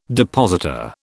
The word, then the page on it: depositor: British English